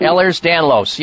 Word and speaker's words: Ehlers-Danlos: But he has had this for years, and they finally diagnosed him with, is it Ehlers-Danlos disease? I probably yes. – Not pronounce it. – Ehlers-Danlos, yes.